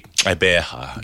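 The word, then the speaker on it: Gqeberha: Did you go Gwebeha? – It's basically Eberha. It comes with the GQ sound that they write it as is a click sound,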